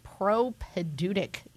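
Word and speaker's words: propaedeutic: So, new seminarians this year are in what is now known as the propedutic year.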